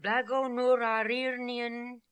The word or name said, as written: Blagonoorarirnian